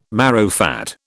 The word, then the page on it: marrowfat: British English